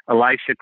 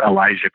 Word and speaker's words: Elisha: But he, Elisha Christian, or Elijah Christian, but it's spelled E-L-I-S-H-A.